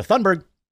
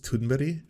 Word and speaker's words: Thunberg: By the way, with Greta, I always hear the Amy pronunciation where it's like almost Thunberg. I want to see the proper pronunciation. Greta. Because I've been saying it in a variety of ways. – We are environmental activists who have recognition for promoting the view that humanity is facing an existential crisis arising from climate change. We are looking at how to pronounce Greta Thunberg. – Thunberg! Ah! Wow, of course Amy was on point.